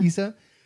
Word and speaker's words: Issa: Issa. – Am I saying it right? – Yeah. – Issa.